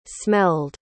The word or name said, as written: smelled